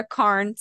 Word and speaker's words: Carne: Sarah Carne, spelled like carne asada.